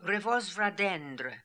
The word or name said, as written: Revozvradendr